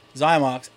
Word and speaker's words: Xymox: Been bands like one of the bands Tom brought me in for homework in the past was a band was that band Clan of Xymox. Am I saying it right? – Yep. – Xymox.